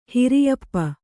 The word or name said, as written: ಹಿರಿಯಪ್ಪ